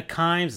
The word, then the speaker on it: Kimes: So I think Mina Kimes, I think that's the way you pronounce her last name. – Yes.